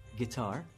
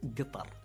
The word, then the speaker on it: Qatar: No, it's not a guitar. It's Qatar. – Qatar. Okay. All right. I like that. I can get into that. That almost is like a France-style pronunciation.